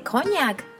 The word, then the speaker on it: こんにゃく: How do you say こんにゃく in English? こんにゃくではありません。こんにゃくですね。